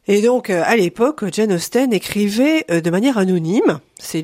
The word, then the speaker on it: Austen: Ça fait une dizaine d'années que j'avais envie d'écrire autour de Jane Austen. D'ailleurs, c'est chouette que vous disiez Austen et pas Austin, parce que ça s'écrit avec un E.